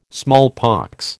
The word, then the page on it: smallpox: American English